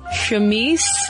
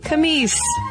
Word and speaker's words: chemise: And Samantha and some other listeners have also filled us in on a mispronunciation of the word chemise, which I woefully misled Caroline into thinking it was pronounced camise. So, my sincerest apologies. I don't know why I thought it was camise.